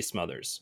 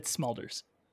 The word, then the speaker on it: Smulders: So I really like Kobe Smulders, mostly because of How I Met Your Mother. Thank you. Like it's Smulders.